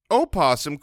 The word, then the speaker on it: opossum: Opossum can be pronounced with the syllable voiced or silent.